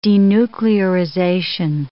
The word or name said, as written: denuclearization